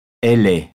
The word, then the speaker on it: elle: On ne prononce pas le E final de elle. – Elle est.